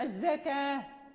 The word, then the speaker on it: الزكاة: مثلا الاصطلاحي الصلاة، يعني هنا نجد الواو لا ننطق بها، الواو لا ننطق بها، هي الواو مرسومة. الزكاة، يعني أين الواو في اللفظ، يعني لا يوافق اللفظ الخط. مثلا السماوات، يعني هنا لا توجد الألف بين الميم والواو في الخط، في خط المصحف.